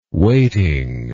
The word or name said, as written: waiting